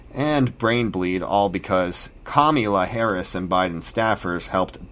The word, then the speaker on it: Kamala: Lionel Timms was bailed out of jail in July thanks to the Minnesota Freedom Fund touted by Biden's running mate, Kamala Harris. Kamala Harris. Let's say her name right. You know, it's racist if you don't. Kamala.